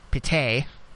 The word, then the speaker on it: Pity: Okay, this name is spelled Pity, but I'm pretty sure it's Pite Martinez of Atlanta United,